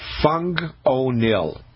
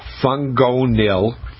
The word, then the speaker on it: Fungonil: But probably the best product on the market is something called Fung O'Neill, and it's spelled FungOnil, Fung O'Neill.